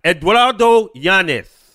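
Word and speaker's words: Eduardo Yáñez: I'm gonna do it like the Spanish people. Eduardo Yanez.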